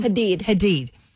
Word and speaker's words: Hadid: Hadid.